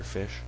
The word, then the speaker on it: ghoti: It's like the joke about naming your dog P-H-Y-D-E-A-U-X. – Fido. – Fido. – Yeah. Mm-hmm. And that G-H-O-T-I, your fish. I think iSpec's cat is named Fish, and it's spelled G-H-O-T-I.